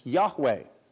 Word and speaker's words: Yahweh: And this word comes from the Hebrew word normally pronounced Yahweh, while older English translations translated it as Jehovah.